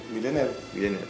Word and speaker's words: Villeneuve: Bueno, primero digamos que el director es el canadiense Denis Villeneuve, ¿cómo se pronuncia? – Sí, Villeneuve.